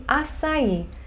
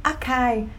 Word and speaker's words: açaí: Que é para vocês é açaí. Mas em inglês, não temos C cedilha. Então, quando eu cheguei no Brasil, eu estava pedindo acai na praia.